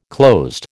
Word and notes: closed: American English

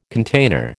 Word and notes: container: American English

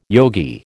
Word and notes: yogi: American English

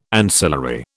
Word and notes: ancillary: British English

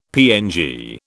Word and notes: PNG: British English

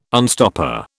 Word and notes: unstopper: British English

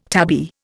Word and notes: tabby: Indian

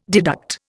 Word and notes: deduct: Indian

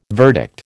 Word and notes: verdict: American English

